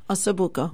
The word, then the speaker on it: Ossobuco: Ossobuco? – Ossobuco.